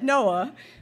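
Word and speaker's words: NOAA: I don't know how to say this, that's how it's said, Noah, we have only explored approximately 5% of the world's oceans.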